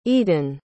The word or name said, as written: edan